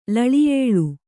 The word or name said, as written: ಲಳಿಯೇಳು